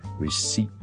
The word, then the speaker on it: receipt: 就唔需要 receipt 咁样嘅，OK，receipt 就得，我哋当系 S-E-A-T 后面咁就可以啦。咁呢个咧就系 silent P。